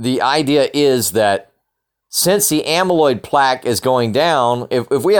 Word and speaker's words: amyloid plaque: Let me check that real quick. Yes, that is how you say it, amyloid plaque.